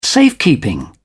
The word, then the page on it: safekeeping: British English